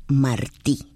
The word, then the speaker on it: Martí: Radio Martí. Con el acento en la I.